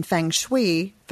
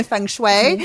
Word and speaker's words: Feng Shui: and you're certified in Feng Shui. I'm sorry, I'm mispronouncing. – Feng Shui. – Feng Shui.